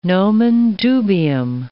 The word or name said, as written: nomen dubium